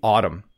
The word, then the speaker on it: autumn: You can just ignore the N at the end of the word. Don't try to pronounce the M and N together. It's simply autumn, okay?